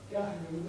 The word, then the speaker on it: Geoghegan: Well, he probably calls it Geoghegan. – Geoghegan, with a short first syllable.